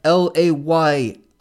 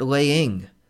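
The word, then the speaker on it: Layin': Paul Canadian Flag at LAYIN SmackDown. Layin SmackDown. Not laying. Layin SmackDown. Like The Rock used to pronounce.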